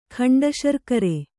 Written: ಖಂಡಶರ್ಕರೆ

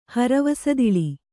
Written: ಹರವಸದಿಳಿ

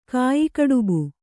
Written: ಕಾಯಿಕಡುಬು

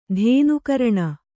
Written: ಧೇನುಕರಣ

